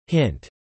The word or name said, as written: hint